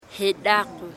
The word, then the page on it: Jedako: Embera Chamí